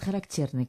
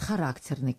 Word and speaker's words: характерный: Вот должна тоже опять здесь остановиться, потому что в Москве говорят характерный класс. А в Ленинграде, в Питере... говорят, характерный класс. И договориться практически невозможно. Поэтому я сейчас, поскольку уже стала жить в Москве, невольно произнесла так, как произносят в Москве.